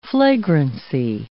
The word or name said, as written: flagrancy